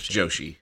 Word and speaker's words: Joshi: Um so yeah, they, they they, just um pump out a, a ton of awesome Yoshi wrestlers. Is it Joshi? Joshi. Am I, res- I okay, yeah. Yes, it's Joshi. Joshi.